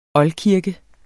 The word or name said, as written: oldkirke